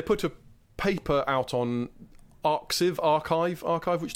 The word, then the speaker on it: arXiv: So it's spelled A-R-X-I-V, but the X is a chi, like a Greek chi letter. – Oh. – So it's archive.